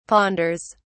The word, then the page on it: ponders: British English